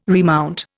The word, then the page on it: remount: Indian